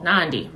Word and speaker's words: Nandi: I am your host, Life Coach Nandu Camille. Nandi rhymes with Gandhi.